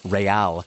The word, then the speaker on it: Real: So apparently it is not pronounced Real Salt Lake. It is pronounced Real.